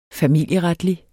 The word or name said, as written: familieretlig